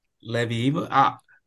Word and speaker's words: Levieva: Pretty much next thing, man, Margarita Levieva. Look, I'm gonna butcher that. – Levieva. – Levieva. Okay. All right, with the Levieva.